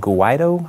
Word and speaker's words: Guaidó: Uh, the entire world pretty much came out in support of their now interim president, uh, which I cannot pronounce his name. Guaidó, I think. So I think that's sure. it is. Yeah, you're good.